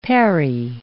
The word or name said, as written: parry